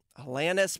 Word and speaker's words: Alanis: Alanis, Brian. – I know, it's hard. I've said it wrong my whole life. – It's confirmed Alanis.